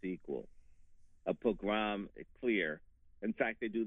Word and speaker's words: pogrom: That's a pogrom. – a program. That's the correct pronunciation.